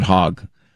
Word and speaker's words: Hogg: Remember this guy, David, is it Hogg or Hogue? I'm not trying to purposely mispronounce it. I think it's David Hogg.